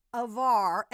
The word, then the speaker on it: Hvar: Hvar, and then they show it. It's spelled like H, pronounced like Havarti.